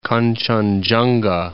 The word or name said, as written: Kanchenjunga